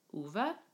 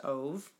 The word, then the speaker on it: Ove: It's called The Man Called Uwe. – The Man Called Uwe? – I thought it was pronounced Ove, because it's spelled O-V-E, but that is incorrect.